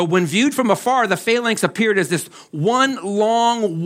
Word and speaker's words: phalanx: It's called the phalanx, P-H-A-L-A-N-X.